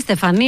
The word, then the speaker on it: Στεφανή: Στεφανή, δεν είναι Στεφανή. – Στέφανη, σιγά μην πει και Τίφανη. – Γιατί Στεφανή, εγώ το βλέπω ότι τονίζεται εδώ πέρα. – Στεφανή, είναι αμερικάνικο.